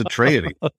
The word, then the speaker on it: Atreides: What's the correct way to say Atreides? – Oh, I'd be happy to do that. And well done you. You nailed it on the first try. It is Atreides.